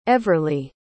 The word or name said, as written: everley